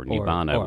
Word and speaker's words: nibbana: Pseudo-nirvana, or nibbana would be the Pali pronunciation.